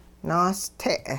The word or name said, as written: naaste'